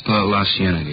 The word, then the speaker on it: La Cienega: Over on, well, street out that way, La Cienega, is that how you say it? – La Cienega, yeah.